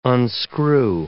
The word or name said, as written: unscrew